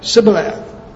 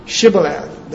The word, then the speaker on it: Shibboleth: These Ephraimites could not say Shibboleth. They couldn't pronounce the SH.